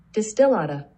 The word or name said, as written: Distillata